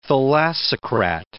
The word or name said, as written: thalassocrat